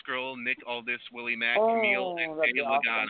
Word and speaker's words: Kamille: Also appearing, NWA National Champion Willie Mack, NWA Vice President David Lagana, and Camille. Did I say that right? – Okay. – K-A-M-I-L-L-E. – Yeah, Camille.